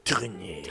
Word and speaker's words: Trenet: Og hun er jo inspirert visstnok av Charles Trenet, eller hvordan du uttaler det. – Trenet. Trenet og Cora Vauquer, eller Vauquer?